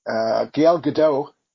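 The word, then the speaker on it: Gal Gadot: So earlier in the week, or at the end of last week, Gail Gadot,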